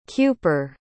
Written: Kuper

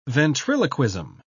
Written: ventriloquism

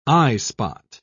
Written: eyespot

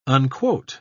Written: unquote

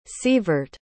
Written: severt

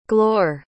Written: glor

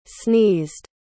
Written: sneezed

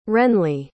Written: wrenlee

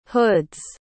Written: hoods

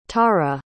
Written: taruh

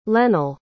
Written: lennell

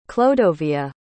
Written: clodovea

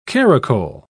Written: caracole

